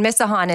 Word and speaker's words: Misajon: And Misahan is M-I-S-A-J-O-N. – Yes. – Because that's right. In my mind, I would have said Misahan. – Yes. Everybody has said it wrong my entire life.